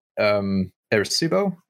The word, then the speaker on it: Arecibo: Arecibo, I believe is how it's pronounced.